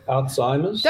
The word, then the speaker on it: Alzheimer's: I know I've heard it Alzheimer's and Alzheimer's, and how do you say it? – Well, I'd actually say Alzheimer's.